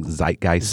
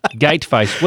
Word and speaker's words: Zeitgeist: Are you saying Zeitgeist? – Zeitgeist. – Did you just completely butcher that? – I did. – That word. – I totally did. Zeitgeist.